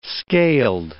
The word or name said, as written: scaled